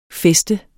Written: fest

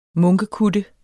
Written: munkekutte

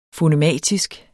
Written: fonematisk

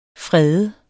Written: FREDENDE

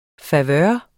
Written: favør

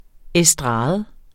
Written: estrade

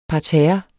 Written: PARTERRERNES

